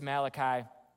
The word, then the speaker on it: Malachi: Maybe some pronounce it Malachi. Maybe the Italians in the room pronounce it Malachi. It's Malachi.